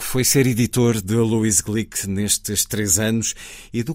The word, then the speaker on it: Glück: Luísa Glick, uh, eh, pronuncia-se assim porque o apelido é de origem húngara,